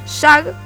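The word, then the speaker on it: jugg: Bueno, como él decía, se juega con una pelota a la que ellos llaman JAG, se escribe J-U-G-G, y unos palos recubiertos con materiales blandos.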